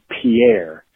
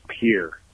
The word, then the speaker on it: Pierre: Sorry, guys, it's Pierre, like the thing that goes into a body of water, not Pierre? Like Pierre, the French dude. It's a weird South Dakota thing.